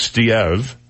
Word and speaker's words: Steeve: Steve Poulin, that's Steve, S-T-E-E-V-E. – Oh. – It's an unusual spelling. – Sure. – It's probably pronounced Steve.